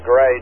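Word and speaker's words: Grey: My name is Gray, just like the color. So it's pretty close to Gray, Jay, but it's spelled with a G-R-E-Y.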